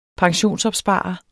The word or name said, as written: pensionsopsparer